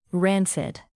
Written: rancid

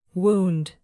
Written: wound

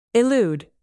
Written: elude

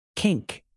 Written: kink

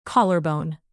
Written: collarbone

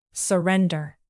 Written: surrender

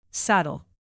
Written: saddle

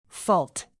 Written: fault

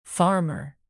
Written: farmer

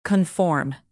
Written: conform

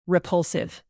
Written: repulsive

